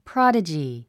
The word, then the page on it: prodigy: prάdədʒi; プロォダジィ